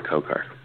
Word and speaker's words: Kokar: It rhymes with my first name, Omar Kokar.